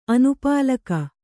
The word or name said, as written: ಅನುಪಾಲಕ